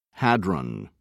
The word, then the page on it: hadron: British English